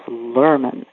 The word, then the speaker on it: Lerman: My maiden name was Lerman, or if you were German, you would pronounce it Lerman, Luhrmann, and that means farmer.